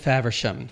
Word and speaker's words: Faversham: Of City Theater's production of Arden of Faversham. And am I pronouncing that correctly? – You are pronouncing it correctly.